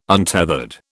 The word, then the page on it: untethered: British English